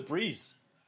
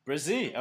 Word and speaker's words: Bresee: Is it Bresee? I thought it was Breeze. Bresee.